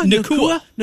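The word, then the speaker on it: Nacua: How do we say his last name? Nakua? – Nakua. – Nakua.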